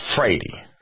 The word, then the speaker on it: Vrede: The guy that came to the United States, the reason he came to the United States, his name was V-R-E-D-E, and his is pronounced Frady, because the V is pronounced like a F.